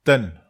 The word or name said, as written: ten